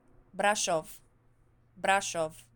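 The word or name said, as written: Brașov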